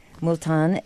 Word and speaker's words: Multan: Multan.